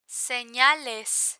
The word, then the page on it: senales: spanish